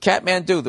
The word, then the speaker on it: Kathmandu: One capital I taught you this week. Kathmandu. For some reason, it's spelled K-A-T-H-M-A-N-D-U. Kathmandu.